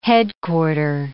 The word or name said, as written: headquarter